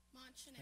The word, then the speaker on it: Montchanin: Is that how you say that? Monchanin? – Monchanin. – How do you spell it? – Well, M-O-N-T-C-H-A-N-I-N. – Monchanin. – Monchanin. Oh, village in Delaware.